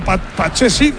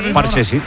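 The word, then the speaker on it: Pathé Ciss: Bueno, pues la bola que la tiene Pedrosa, tocando cuero para Pate Cis. ¿Ha visto cómo se dice ahora? Pache Cis. – Sí, lo he entendido, Pache Cis.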